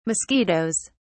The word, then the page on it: mosquitos: British English